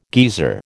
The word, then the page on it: geezer: American English